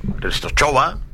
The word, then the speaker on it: Częstochowa: Es que yo creo que la W en Polonia es como en Alemania, que se pronuncia como una B. Częstochowa.